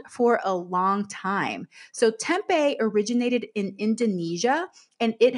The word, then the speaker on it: tempeh: So that is spelled T-E-M-P-E-H, but it's pronounced tempeh.